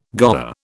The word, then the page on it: goer: British English